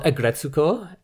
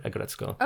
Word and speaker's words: Aggretsuko: It's called Agretsuko. And that is spelled A-G-G-R-E-T-S-U-K-O. Or sometimes it's called Aggressive Retsuko in depending on where you are. But on Netflix, it's just one word, Agretzko.